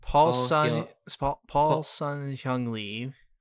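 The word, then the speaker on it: Paul Sun-Hyung Lee: And he really gets along well with Paul Hyung-seo. How do you pronounce his name? Who plays Iroh? – Let me see. – He's in the Kim's Convenience. I'm looking at Paul Sun Hyung Lee.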